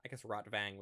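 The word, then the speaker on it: Rotwang: And so he takes the map fragments to his old buddy, Rotvang would be the more accurate pronunciation, who is a mad scientist who was in love with Freighter's mother.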